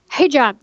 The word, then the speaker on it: hijab: Well, tell me, what does it mean to you to wear your hijabi? Am I saying that right? – Hijab. Yeah. – Hijab. No, there's no I at the end.